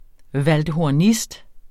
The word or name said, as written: valdhornist